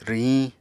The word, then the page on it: rwhiñ: Apatani